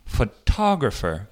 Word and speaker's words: photographer: 근데 photographer 발음이 달라져요. 그래서 photo 그 모음 소리는 철음절이 어, 원래 강조하는 거고 photo 할때 근데 photographer 사진사 할 때는 그게 두 번째 음절로 옮겨요.